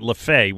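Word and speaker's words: Lefebvre: Not only do I remember Joe LaFay, but I remember that he spelled his name Lefever, but pronounced it LaFay, which I can't tell you why. It's a decent one.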